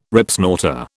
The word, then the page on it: Ripsnorter: British English